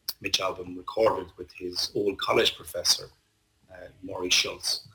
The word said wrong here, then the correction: Schwartz: Schwartz is the correct pronunciation.